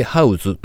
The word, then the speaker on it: Houzz: で、この記事の中で紹介されていた家づくりプラットフォーム h o u z HOUZZ てハウズと読むようなんですが、そのサイトではですね、ホームオフィスのアイデアを集めた記事や写真が掲載されていました。